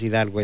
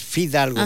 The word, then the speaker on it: Fidalgo: Álvaro Fidalgo. – Álvaro Fidalgo, no es Hidalgo, es Fidalgo.